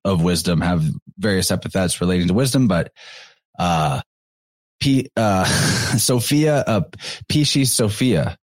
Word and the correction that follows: Pistis Sophia: So you have the idea of, Pistis Sophia, I'm sorry, not Pisces. Pistis Sophia, but that's very phonetically close to Pisces or Pisces.